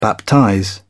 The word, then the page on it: baptize: British English